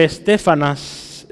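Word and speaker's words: Estéfanas: La familia de este hombre, Estefanas, eh, lleva un acento en la segunda E, de Estefanas, es, muy interesante.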